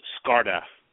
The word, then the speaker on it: Scarda: It's just Scarda.